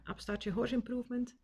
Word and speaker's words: Upstart Horse Improvement: Ehm, Sarina kan je volgen eh, via Insta, uh, eh, Upstart Horse Improvement, denk ik. Zeg ik dat goed? – Ja. ja.